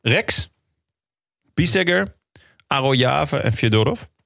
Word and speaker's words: Fyodorov: Maar het is met een J ook. Fyodorov. – Ja, de E in het Russisch is een J. Fyodorov.